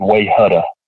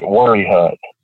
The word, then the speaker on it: Wayehutta: But at the same time, you know, there are things like there's a place here that it's spelled W-A-Y-E-H-U-T-T-A, which to anybody else would look like Wayhutta. But we pronounce it Worryhut.